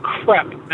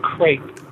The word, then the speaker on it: crepe: Pronounced crepe. It is a French word, and it is pronounced crepe, not crepe.